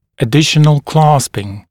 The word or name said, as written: additional clasping